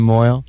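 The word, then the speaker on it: mohel: I don't like the name Moyle. It rhymes with boil.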